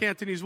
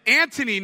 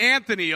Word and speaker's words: Antony: It's Antony, not Anthony, okay? Get the name right. It's Antony. Mark Antony.